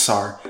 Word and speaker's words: Sar: Hi everyone, it's your host Sar. My name rhymes with car,